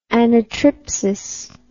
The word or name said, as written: anatriptic